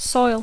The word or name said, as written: soil